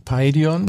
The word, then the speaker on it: Paidion: Ich kann es nicht aussprechen. Paidon? – Paidion. Paidion. Das ist altgriechisch, heißt das Kindlein.